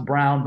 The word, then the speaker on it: Braun: I know it's pronounced Brown, but it's Braun to me because that's how it's spelled.